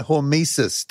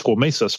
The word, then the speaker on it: hormesis: Well, I want to know about hormesis. Did I get the pronunciation correct? – Yeah.